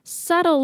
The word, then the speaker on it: subtle: Subtle, 如果你看它的拼字的话，你会发现它有一个 B, 但是那个 B 呢是不发音的哦，所以是念 subtle.